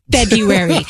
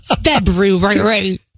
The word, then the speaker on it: February: Number two rule of crisis management, don't pronounce the first R in February. It's February, not February.